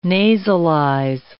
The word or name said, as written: nasalize